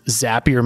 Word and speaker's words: Zapier: Well, I think they're their slogan is Zapier makes you happier. So it rhymes.